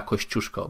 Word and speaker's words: Kościuszko: And it is pronounced Andrzej Tadeusz Bonaventura Kościuszko, by the way.